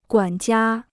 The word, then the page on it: 管家: guǎn jiā